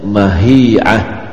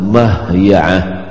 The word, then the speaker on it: mahya'ah: Ini ada lagi membacanya mahya'ah. Tapi yang benar itu adalah mahya'ah.